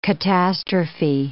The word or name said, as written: catastrophe